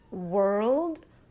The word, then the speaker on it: world: Now the word word, we have no L sound. It is word, word. So let's listen to the difference. World, word, world, word.